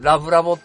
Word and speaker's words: 恋愛ラボ: 恋愛ラボと書いて、うん、ラブラボって読むんですけど、ラブラボです、ラブラボ。